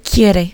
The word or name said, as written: quiere